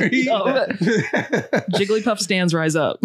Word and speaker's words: Jigglypuff: Did I say it right? – No. – How do you say it? – Jigglypuff? – Jigglypuff. – It's a Hillbillypuff. It like, Jigglypuff. Girl. And I was like, oh, damn, well, I got that wrong.